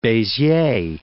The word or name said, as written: Beziers